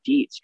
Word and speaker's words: Dietz: That way you can go, okay, God, who is Coach Dietz? Because like, my name's, people mispronounce it all the time. Dietz, Dietz. Oh, I guess, yeah, I emailed him.